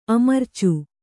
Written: ಅಮರಿಚು

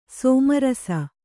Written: ಸ್ಮರಿಸು

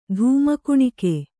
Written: ಧೂಮಕುಣಿಕೆ

